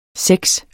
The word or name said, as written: sex